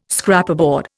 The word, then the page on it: Scraperboard: Indian